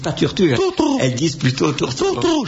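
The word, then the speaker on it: turtur: Oui, excusez-moi, on a la certitude que les latins prononcent bien où, parce que la tourterelle, en latin, se dit, s'écrit et se prononce tourtour. Or, si vous l'écrivez en français, c'est turture.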